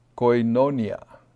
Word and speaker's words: koinonia: And it's the word in the Greek pronounced koinonia.